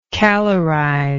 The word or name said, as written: calorize